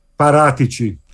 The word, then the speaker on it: Paratici: So that brings in Fabio Paratici. Excuse my pronunciation. So give it to me again. – Paratici.